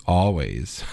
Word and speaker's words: Always: In the middle, it is the always delightful, sugary indie rock band, Always. I didn't mean to say it like that, but yes, Always. Always is the band.